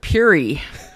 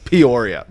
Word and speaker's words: Peoria: Okay, here's what it is. The people who went to Peoria are like loud and proud. And it's Peoria. But everyone else is like, it's Peary. It's too many syllables.